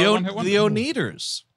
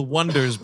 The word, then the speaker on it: Oneders: The Oneaters. – Yes. – Pardon me? – The way they call themselves the Wonders, but the way they spell it is O-N-E-D-E-R-S, and everybody keeps saying Oneaters.